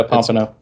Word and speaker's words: pompano: I've been pronouncing pompano completely wrong then. Pompano, right? That's it. – Oh, yeah. Yeah, pompano.